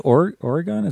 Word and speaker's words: Oregon: Is it Oregon? Is that right? How do you pronounce that? – Oregon. – Oregon. It didn't sound right to me.